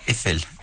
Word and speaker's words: Eiffel: Eiffel.